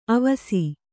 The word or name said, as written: ಅವಸೆ